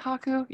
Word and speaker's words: Haku: I thought, is it Haku? Yeah, it's still how you pronounce his name.